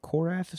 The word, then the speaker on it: Korath: Korath is how you'd pronounce that.